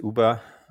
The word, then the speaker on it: Uba: Shortly, just Uber. It's a bit easier to pronounce.